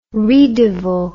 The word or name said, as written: ris de veau